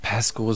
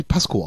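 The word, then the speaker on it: Pascow: Pesco sind eine geile Punkband. – Spricht man sie Pesco aus? Ich dachte immer, wenn wir die Pasco aussprechen, also deutsch.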